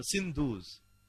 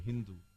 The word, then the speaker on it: Hindu: Those people on the other side of the Sindhu River, they were Sindhus. When they pronounced this word Sindhu, what it became? It became Hindu. So this word Hindu is derived from the mispronunciation of a Muslim.